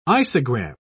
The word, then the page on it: isogram: British English